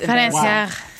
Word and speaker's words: financier: That's financier. – How do you say that? That's humiliating. – Say it. – Just turn this podcast off right now. – Financier? – Financier. – Financier. Yeah, financier.